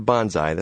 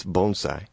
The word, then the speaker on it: bonsai: It's not bonsai. That's what you yell when you jump out of an airplane. It's bonsai.